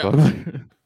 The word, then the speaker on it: Vogler: Is that how you pronounce his name? Vogler? – Yeah. – Vogler? – Yeah.